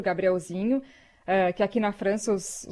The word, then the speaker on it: Gabrielzinho: os apresentadores chamam de Gabriel Zinot, porque os franceses não conseguem pronunciar o NH, né? Eles colocam o acento sempre no final e não sabem falar o Nho, né?